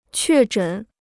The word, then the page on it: 确诊: què zhěn